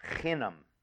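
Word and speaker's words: chinam (חנם): And the Hebrew word here for nothing is a word that's pronounced chinam.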